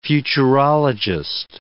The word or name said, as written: futurologist